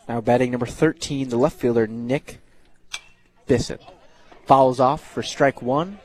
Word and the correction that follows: Bisson: I think it's pronounced Beeson. – Beeson, it is. Yep. It is pronounced Beeson.